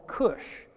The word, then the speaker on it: Kush: Well, today we're talking about one of those places, and it's called Kush. And typically in English, it's spelled with a C, all right? However, I have seen it spelled with a K, and it's Kush, not like Hindu Kush, if, if you're familiar with the area in the Middle East.